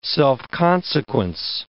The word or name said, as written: self-consequence